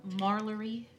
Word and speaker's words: Marlori: I said the name of Marlory Shy Dare. – Marlory Shy Dare. – M-A-R-L-O-R-I.